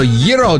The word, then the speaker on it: gyro: Also, gyro day. Did I say it right? – Yes. – Gyro, not Bravo.